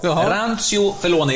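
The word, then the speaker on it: Rancio Fellone: Ja, Rancho Felone, är det rätt uttal? – Ja, det låter jättebra. Ja.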